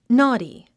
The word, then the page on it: naughty: nɔ́ːti; ノーティ